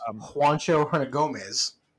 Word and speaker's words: Juancho Hernangómez: We have Juanico Hernangómez as Bo Cruz. I did not say that right, and I'm sorry. – It's Juancho Hernangómez.